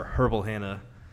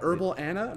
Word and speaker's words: Herbal Hannah: So to start the plug here, is you guys are Herbal Hannah soaps. And yes, thank you for saying it correctly. – Right. – Yeah, you got to pronounce the H's, because if not, people are like Herbal Anna.